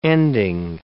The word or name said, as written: ending